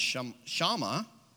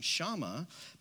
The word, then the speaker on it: Shammah: Jesse then had Shammah," I looked up the pronunciation this morning, "Shammah pass by, but Samuel said, 'Nor has the Lord chosen this one.'